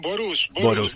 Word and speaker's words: Borús: Borús, perfecto. Con acento en la U, es una palabra húngara que quiere decir nublado, lamentablemente.